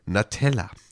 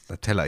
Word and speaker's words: Natella: Eines Italieners mit dem Namen Andrea Natella. Natella, ich habe das so schön italienisch ausgesprochen. Andrea Natella, natürlich. – Natella. – Natella. Genau. Die Nutella nur mit A quasi. Und U, also ohne U mit A.